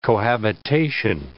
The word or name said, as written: cohabitation